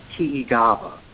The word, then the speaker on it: Tiigava: And in their native language, this was referred to as Tiigava, which is spelled T-I-I-G-A-V-A, and it means to gather.